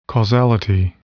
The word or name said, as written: causality